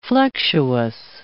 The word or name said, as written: flexuous